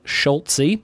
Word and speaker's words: Schultze: He says it's pronounced like Schult-Z. In your head, throw a T before the Z and then pronounce the Z like the letter.